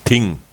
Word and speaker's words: Thing: Wie wird es ausgesprochen? Ist das nicht dein Event? – Das Ting. – Ah, Ting, okay.